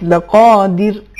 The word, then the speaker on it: لقادر: چونکہ یہ حرف ساکن ہے اور اس سے قبل حرکت زیر ہے، اس صورت میں حرف را کی ترقیق ہوگی. ایک مرتبہ اس آیت کی تلفظ کیجئے لیکن ترقیق کے ساتھ، لقادر.